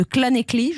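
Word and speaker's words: Llanelli: Le Racing 92 l'a emporté chez les Gallois de Clanecly.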